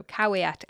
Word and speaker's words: caveat: The pronunciation is actually very easy if you bear in mind that V's are W's. So caveat emptor.